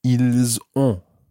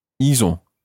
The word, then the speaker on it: Ils ont: And that's spelled O N T. Ils ont. – Ils ont. – and make sure that you you sort of make that an on sound as opposed to an on sound on